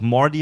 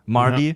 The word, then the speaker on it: Marty: Und bei Zurück in die Zukunft wird im Englischen der Marty auch, es wird immer so ausgesprochen, dass es auch Morty heißen könnte. Marty. Ja. – Marty.